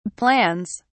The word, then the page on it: plans: British English